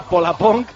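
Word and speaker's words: Pollapönk: Pues, se escribe Pollapong, supongo que es... Ese es el nuestro. – En islandés se dirá Polapong.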